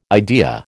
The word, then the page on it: idea: American English